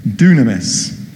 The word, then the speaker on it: dunamis: Like all the examples of power that we looked at last week, the word comes from the Greek word dunamis, or it's spelled dynamite, D-Y-N, dynamis, but it's pronounced dunamis.